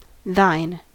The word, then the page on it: thine: American English